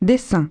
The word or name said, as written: dessin